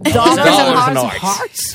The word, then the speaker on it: Dollars and Hearts: Faut que tu le dises avec l'accent, par exemple. C'est comme ça que ça se prononce. – Dollars and Hearts.